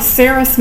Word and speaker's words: serous: Our next type is called a serous membrane. It looks like it's spelled serious, but it's not.